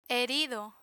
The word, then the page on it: herido: Spanish